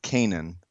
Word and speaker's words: Canaan: Is it Canaan? How do we say it? – Uh, I've always pronounced it Canaan. Okay, I thought maybe that's Kanan wrong too. I thought it was Canaan too.